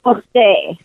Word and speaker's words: Pret-A-Porter: Pret-A-Porter. It's like Porter, but it's pronounced a little bit differently because, you know, every language has their own phonetics and so forth.